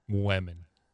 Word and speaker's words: women: It's almost like W-I-M. – Oh, women. – Yeah. – Women. – Yeah. – Okay. – It's definitely not W-E-M.